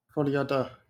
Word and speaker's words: Folie à deux: Folie à deux, yeah.